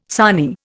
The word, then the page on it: sarnie: Indian